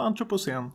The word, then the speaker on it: antropocen: Det har börjat talas allt mer om ett begrepp, nu vet jag inte riktigt om jag uttalar rätt, antropocen. – Ja, antropocen ja.